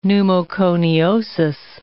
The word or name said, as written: pneumoconiosis